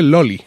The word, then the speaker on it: Lolly: Vamos a hablar de Loli. Loli, no una vecina mía, la Loli, sino que se escribe L-O-L-L-Y.